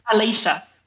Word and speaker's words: Alischa: Am I pronouncing your name right? Is it Alicia or Alicia? – Alicia.